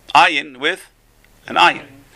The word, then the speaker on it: ayin: So ayin is related to the word ani. So the word ayin with an R, with an ayin. You got to pronounce it gutturally.